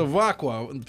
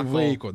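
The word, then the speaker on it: Waco: Ну, пишется Вакуа, читается Вейко, да? Вейко.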